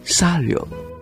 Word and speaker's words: Chariot: 仅仅两年之后，他就开始录制法语的歌曲，其中最值得注意的就是他的首 1962 年灌录的 Chariot，法语的发音呢好像是 Sario，他的这首歌得到金唱片。